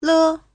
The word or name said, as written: le5